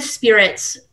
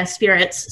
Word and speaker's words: Speirits: Yeah, the E is silent. So it's the E that throws people off. Yeah, it's right. Not the E that gets people. But yeah, Speirits.